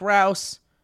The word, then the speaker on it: Rouse: My name is Jake Rouse, pronounced like house.